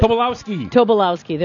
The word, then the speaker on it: Tobolowsky: Tobolowsky.